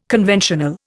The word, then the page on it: conventional: Indian